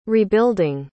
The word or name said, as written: rebuilding